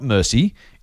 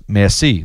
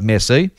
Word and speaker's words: merci: He finishes off saying, "Merci," and in brackets, "not mercy, it's pronounced merci."